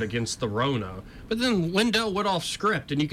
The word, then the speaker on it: Lindell: I was getting his last name wrong earlier. Mike Lindell, CEO of MyPillow.